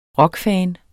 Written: rockfan

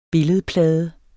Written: billedplade

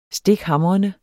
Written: stikhamrende

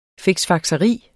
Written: fiksfakseri

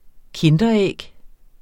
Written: kinderæg

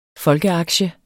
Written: folkeaktie

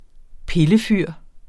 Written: pillefyr

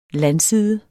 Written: landside

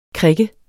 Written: krikke

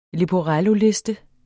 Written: LEPORELLOLISTEN

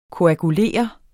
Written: koagulere

